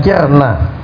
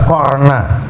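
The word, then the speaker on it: qarna (قَرْنَ): Jadi yang masyhur itu ikirna bacanya kirna. Adapun yang merupakan bacaan Nafi dan Asim dibaca qarna ya, qafnya di fathah.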